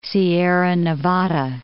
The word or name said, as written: Sierra Nevada